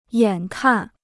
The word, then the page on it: 眼看: yǎn kàn